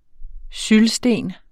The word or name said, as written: syldsten